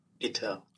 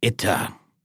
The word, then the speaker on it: Itter: And just for fun, let's see what Forvo says about the pronunciation of this castle. – Itter. – I got it. Itter. I love the German intensity.